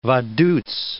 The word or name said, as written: Vaduz